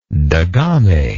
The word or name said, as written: degame